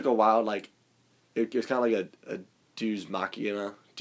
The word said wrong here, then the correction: deus ex machina: deus ex. – Yeah. – Not machina, just, what's it called? Deus ex machina. Deus ex machina. – You know, am I saying that right? – Yeah. – You know what those are, right? – Deus ex machina? – Deus ex, that's better. – Yeah. – Deus ex machina. Is right.